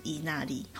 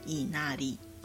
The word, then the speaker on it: 稲荷: 那日文就是伊那利，好，稻荷其实就念成伊那利。